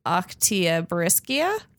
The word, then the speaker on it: Oktyabrskaya: It took place at a long dismantled bridge over the, Umba River near the abandoned settlement of Oktyabrskaya. Wow, you said it right.